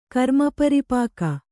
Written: ಕರ್ಮಪರಿಪಾಕ

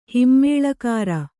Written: ಹಿಮ್ಮೇಳಕಾರ

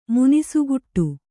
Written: ಮುನಿಸುಗುಟ್ಟು

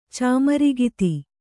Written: ಚಾಮರಿಗಿತಿ

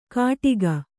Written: ಕಟಿಗ

